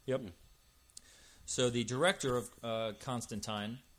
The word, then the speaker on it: Constantine: Is that how you say it, Constantine? I've always said Constantine. – I've always said Constantine, and in fact, I have to force myself to remember to say Constantine. But according to Alan Moore, folks, their actual pronunciation is Constantine.